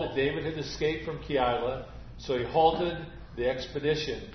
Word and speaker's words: Keilah: Thus ends the reading and hearing of God's holy word, and the town's name is Keilah. I did look it up. That is a correct pronunciation.